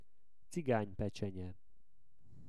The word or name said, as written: ciganypecsenye